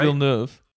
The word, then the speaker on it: Villeneuve: Is that how you say his name, Villanova? Man, I was gonna. I was saying. – It's not. – How do you say it's Villanueva, right? – Villeneuve.